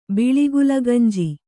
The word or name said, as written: ಬಿಳಿಗುಲಗಂಜಿ